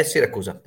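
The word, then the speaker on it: Siracusa: So I quite enjoyed Matera, and then also Syracuse, Syracusa. How do I say that? – Yeah, Syracusa. Perfetto.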